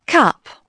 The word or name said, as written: cup